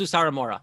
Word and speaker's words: Sawamura: Sawamura. – Like, Sawamura. That's how you say his last name. Hirokazu Sawamura.